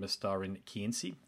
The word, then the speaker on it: Mestarin kynsi: So, look, it's a fairly long opening statement there, but I really want to offer you a heartfelt congratulations on Mestarin kynsi.